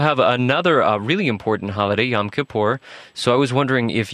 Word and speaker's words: Yom Kippur: And 10 days later, after the Jewish New Year, there's the Day of Atonement, correctly pronounced Yom Kippur.